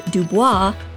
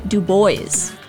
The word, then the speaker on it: Du Bois: It is not W.E.B. Du Bois, it is W.E.B. Du Bois.